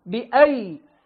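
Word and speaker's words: بأييد: ولذلك لما تطالع المصحف تجدها كتبت بيائين: والسماء بنيناها بأيد، كتبت الباء والهمزة وبعدها ياء وياء. الياء الأولى وضع عليها صفر مستدير لا تنطق، والياء الثانية وضع عليها سكون بأيد.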